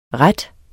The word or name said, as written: rette